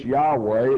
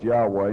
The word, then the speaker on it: Yahweh: It's Yahweh. It should be pronounced Yahweh instead of Jehovah.